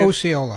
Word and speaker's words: Osceola: So it actually had a decently star-studded cast, but the reason that we're talking anyway about that film is because residents of, is it Osceola? Is that how you say it? – Osceola.